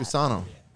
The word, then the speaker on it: gusano: Gusano. – Gusano. – Yeah. Can you spell it? – G-U-S-A-N-O.